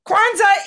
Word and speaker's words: Kwanzaa: Kwanzaa is it for 10 points.